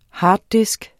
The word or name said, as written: harddisk